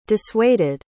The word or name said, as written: dissuaded